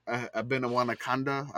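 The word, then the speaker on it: Abanikanda: Abenawanakanda, I believe I said that wrong again. – I think you got that right.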